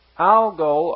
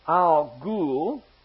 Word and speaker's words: Algol: Algol, or sometimes pronounced Al-Ghul.